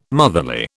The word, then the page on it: motherly: British English